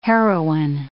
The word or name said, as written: heroin